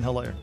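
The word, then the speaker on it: Hilaire: The H is silent, by the way, in Helaire. – So I said Helaire. – Helaire. – I heard an H. – Helaire. – It's silent. – It's like Helen.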